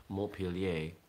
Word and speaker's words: Montpelier: If I wanted to pronounce that with a proper French accent, it would be Montpellier, Vermont. Right?